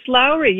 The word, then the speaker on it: Lourey: It's Lourey.